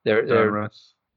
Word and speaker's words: Donruss: Is that how you say it, Donruss? – Donruss. – Yeah, Donruss cards. I got some large ones.